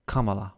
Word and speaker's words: Kamala: It's like Canada, except Ka instead of the Kamala.